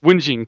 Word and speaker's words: whinging: How do they say it? Winging? How does that word pronounced? – Whinging, I think. – Whinging. Quit your whinging.